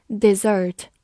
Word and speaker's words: dessert: 好，最后来对比一下，跟它长得非常相像的一个单词，就是双写 s，发音呢跟它的动词一样，dessert。 dessert，它是一个名词，表示甜点，所以一定要注意区分一个 s 和两个 s，它们的发音和含义都有非常大的区别哦。